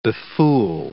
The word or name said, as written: befool